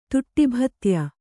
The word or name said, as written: ತುಟ್ಟಿಭತ್ಯ